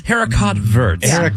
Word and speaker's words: haricot vert: Haricot verts. – That's green beef. – Yeah. – What is it? – Haricot vert. – Is that how you say it? – Yeah. – Yeah.